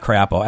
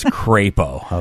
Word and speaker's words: Crapo: Excuse me, it's not Crapo. Everybody knows that. It's Crapo, okay? – Oh. – It's spelled C-R-A-P-O, but apparently it's a capital A. So it's Crapo.